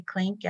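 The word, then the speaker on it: Klinck: My last name is spelled K-L-I-N-C-K. I'm on Facebook and Instagram at Leslie Klink at Leslie Klink Closet Factory Denver.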